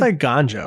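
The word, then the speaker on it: Eiganjo: Did I pronounce that correctly? – I think it's Iganjo.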